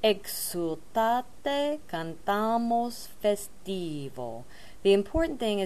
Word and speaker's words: Exultate: It is an S the entire time, and make the ek sound more like an EG sound, like a hard G. Egg. Exultate, cantamos festivo.